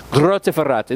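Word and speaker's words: Grottaferrata: When we moved to Grotta Ferrata, that's how you say it, Grotta Ferrata.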